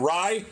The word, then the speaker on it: Rai: Aaron Ryebread, but it's spelled R-A-I, like Ray. Watch out for him. He plays on both tours. Aaron Rye, 70-1.